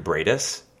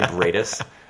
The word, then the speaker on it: Briedis: Hi, my name is Andrew Briedis, not Andrew Keenan Bulger. My name is Andrew Briedis. That's spelled B-R-I-E-D-I-S. It's Lithuanian for reindeer, and everyone's going to mispronounce it for the rest of my life.